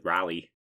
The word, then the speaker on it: Raleigh: So we I've always pronounced it rally. – Oh, like a rally? – Yeah, like a rally.